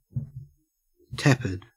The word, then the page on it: tepid: Australian English